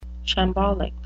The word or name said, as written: shambolic